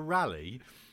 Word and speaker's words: Raleigh: R-A-L-L-Y-E. – The famous historical figure, Sir Walter Raleigh,